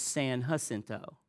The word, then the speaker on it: San Jacinto: And the fella said, no, out here, sir, the J's are pronounced as H's. It's San Jacinto.